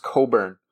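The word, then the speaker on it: Cockburn: Also, I've heard his name is pronounced Coburn, which does not make sense based on how it's spelled. But apparently, it's Kofi Coburn or Coffee Coburn. I think I've never heard a CK be silent before.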